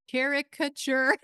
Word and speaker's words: caricature: It's to angry people who are, I would say the caricature, how do you say this word? Caricature.